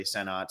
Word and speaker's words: Sennott: Sennott. Thank you. I mean, it's two T's, which is what throws me off because I feel like you would pronounce it O if it was like just one I might T. be wrong too, though, but I think it's Sennott.